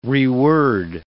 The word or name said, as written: reword